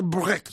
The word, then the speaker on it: Brecht: Hay que pronunciar Brecht, según me ha dicho alguien con sapiencia alemana.